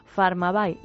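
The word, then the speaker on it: Pharmabuy: Recuerden que Farmabay se escribe con PH y Bay es como comprar pero en inglés. Farmabay, la tienda online de farmacias.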